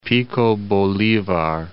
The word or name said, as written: Bolivar, Pico